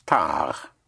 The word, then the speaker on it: tard: Like the word for late in French is tard. Tard, spelled T-A-R-D, like in tardy. But you don't sound the D because it is spelled T-A-R-D without an E. Yes, tard.